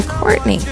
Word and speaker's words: Courtnie: Well, no, it's just more so because, all right, my first name is actually Courtney, but it's spelled C-O-U-R-T-N-I-E.